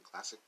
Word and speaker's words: Klacik: Uh, that is uh, Kim uh, Cl- Klacik. My bad. Yeah, yeah, yeah. I said it right. Kim Klacik.